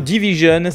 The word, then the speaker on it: DVSN: Enfin, direction euh, le Canada à la rencontre du duo Division, ça s'écrit DVSN, et c'est euh, Isaac qui nous parle de cet album RB, Amuse in Her Feelings.